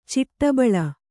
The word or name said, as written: ಚಿಟ್ಟುಬಾಳೆ